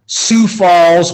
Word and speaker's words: Sioux Falls: He's the head girls basketball coach at Sioux Falls, Washington High School. Did I say that correctly? – That is correct.